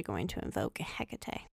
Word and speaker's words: Hecate: There are multiple ways to spell it. But we are going to say Hecate. I think in Practical Magic, they pronounce it Hectate. It always made my eye twitch a little bit.